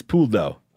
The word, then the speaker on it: Pouldeau: The correct answer is Puldo. – Is that how you spell it? – You're close. It is P-O-U-L-D-E-A-U. So you're in the ballpark.